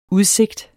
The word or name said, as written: udsigt